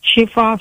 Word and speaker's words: Chiefare: Chez Fa Marilyn. – Alors, le nom de famille, comment ça s'écrit? – C-H-I-E-F-A-R-E. – C-H-E-F-A-R-E.